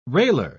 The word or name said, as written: railer